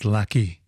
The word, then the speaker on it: Lachy: It's spelled L-A-C-H-Y, but he pronounces it Lucky. Maybe that's just the Australian pronunciation, Lucky Dolly.